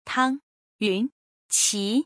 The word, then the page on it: 汤云琪: Tang Yunqi